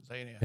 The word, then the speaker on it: Zanon: All right, so I know you're excited about your, how do we say it, Zanon books? – Yeah, Zanon. – Am I saying it correctly? – Correct. Uh-huh.